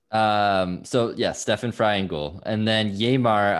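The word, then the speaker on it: Stefan Frei: Stefan Fry.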